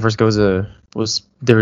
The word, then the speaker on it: Gose: It's spelled G O S E, but pronounced Gose.